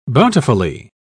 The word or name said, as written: bountifully